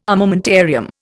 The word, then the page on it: armamentarium: Indian